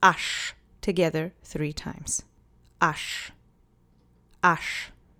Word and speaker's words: aż: The next word is aż. I know it says aż, but nobody ever says it that way. It's pronounced with the SZ phoneme sound. Sh.